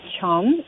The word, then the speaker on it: Tuam: Well, you know, in addition to what was going on in Dublin, there was also the protest in a town by the name of Chum, from what Kevin said is the pronunciation. It's spelled T-U-A-M.